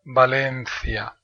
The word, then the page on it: Valencia: Spain